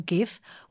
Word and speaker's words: GIF: The graphics interchange format, or GIF, was created in 1987 by CompuServe employee Steve Wilhite, who insisted at the 2013 Webby Award show that the format was always intended to be pronounced as GIF with a soft G, as a nod to the American peanut butter brand, GIF.